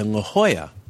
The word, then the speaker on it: La Jolla: And I went to La Jolla. It's spelled L-A-J-O-L-L-A.